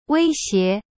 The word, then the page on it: 威胁: wei xie